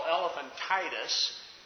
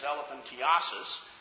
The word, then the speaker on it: elephantiasis: It might be what many people mistakenly call elephantitis, and really the correct pronunciation is elephantiasis.